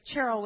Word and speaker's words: Cheryl: And so Cheryl with a hard CH is the Hebrew or the Jewish way to pronounce her name.